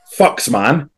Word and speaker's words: Fuxman: F-U-X-M-A-N. Laurie Dunstar messaged me before the game and said, how do I pronounce that? I'll tell you how you pronounce that now. Fucks, man.